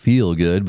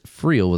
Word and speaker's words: Freel: Ben's been butchering it by calling it Feel Good, but it's Freel with an R. So F-R-E-E-L, Good Performance.